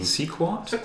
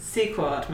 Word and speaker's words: Sequart: Women who make comics, women in comics culture, by Marissa Stotter, and it was produced by, I'm not sure how to pronounce this, it's Sequart, Sequential Art Organization. – Sequart. – We're going to sound like parrots regardless. Possibly cockatiels. I don't know. – Sequart makes the most sense.